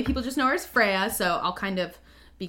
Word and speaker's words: Freyja: So first and foremost, the medieval Norse pronunciation for her name is Freyja, which I sometimes... – Freyja? – Is Freyja, yes.